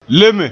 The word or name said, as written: leme